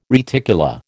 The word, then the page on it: reticula: American English